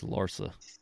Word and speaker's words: Larsa: Your favorite player dude who hang around with a freak named Larson, bro? – Why do you keep saying Larson? – That's how you say it, Larsa or Larson? – It's Larsa.